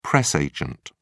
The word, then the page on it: press agent: British English